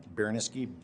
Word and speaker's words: Baraniski: Brian Baraniski, B-R-Y-A-N, Baraniski, B-A-R-A-N-I-S-K-I.